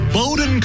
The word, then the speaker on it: Bowdoin: Hello to Chris in the North Hills who called up and said, I mispronounced the name of his alma mater. Chris, I beg your pardon, grant your grace. Hope the cattle spit in my face. He said it's Bowdoin College, and the mascot is the Polar Bears.